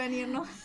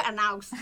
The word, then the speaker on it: Ernaux: Ay, no, sé si se pronuncia Ernaux. – Yo también le digo Erno. Me encanta, es que se debe ser así. – Yo bruta le digo Arnaux.